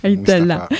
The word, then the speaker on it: Ait Alla: Et aujourd'hui avec nous, ambiance légère, nous accueillons Mustapha Ait Allah, j'espère que j'ai bien prononcé ton nom de famille. – Ait Allah. – Mustapha, bonjour.